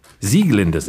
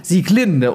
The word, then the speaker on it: Sieglinde: Sieglinde sehe ich hier gerade aus der Walküre, weil nämlich alles bei Wagner auf der ersten Silbe betont wird.